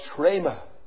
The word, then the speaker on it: rhema: And it's pronounced rhema.